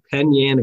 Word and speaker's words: Penn Yan: You played high school at Penn Yan Academy.